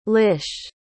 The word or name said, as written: lish